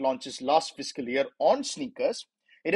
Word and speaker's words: SNKRS: Nike's digital app, Sneakers, the pronunciation is S-N-K-R-S. It's pronounced as sneakers, but it's written as S-N-K-R-S,